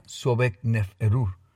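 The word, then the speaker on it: Sobekneferu: El último gobernante de la dinastía duodécima fue la reina Sobekneferuf, que no tenía hijos, eso se pronuncia Sobekneferuf.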